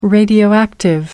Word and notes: radioactive: American English

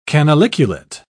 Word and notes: canaliculate: British English

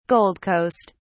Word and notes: gold coast: British English

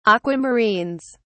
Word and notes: aquamarines: British English

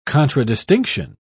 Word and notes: contradistinction: British English

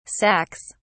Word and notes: sacs: British English

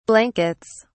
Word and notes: blankets: British English

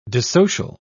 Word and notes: dissocial: British English

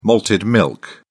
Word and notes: malted milk: British English

